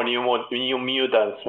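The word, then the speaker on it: New Mutants: Es un, estamos ante un nuevo New, Mutants, me parece. Mm. ¿Lo dije bien, Tommy? Impecable, impecable.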